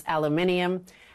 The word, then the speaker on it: aluminium: And so he slapped some tariffs on their steel and aluminum, or as the Brits like to pretend it's pronounced aluminium.